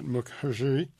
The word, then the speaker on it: Mukherjee: This is by Siddhartha, and I'll get the last name wrong, but Mukherjee.